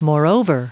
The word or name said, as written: moreover